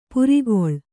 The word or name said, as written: ಪೆರ್ಕಳ